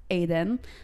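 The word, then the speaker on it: Aden (עדן): or as it's pronounced, Aden.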